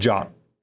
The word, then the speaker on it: JWT: That's JWT for a JavaScript web token, but it's pronounced or a JSON web token, but it's pronounced JOT.